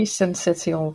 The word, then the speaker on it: syncytial: How do you say that? Second word. – It's respiratory syncytial virus.